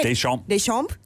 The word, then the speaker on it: Deschamps: Is Didier Deschamps. Did I say it right? – Deschamps.